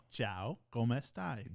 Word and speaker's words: Ciao, come stai: Is it Chaco mastai? – No. Ciao, come stai? – Ciao, come stai?